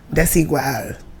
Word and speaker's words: Desigual: Desigual.